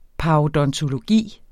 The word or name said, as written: parodontologi